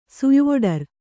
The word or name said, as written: ಸುಯ್ವೊಡರ್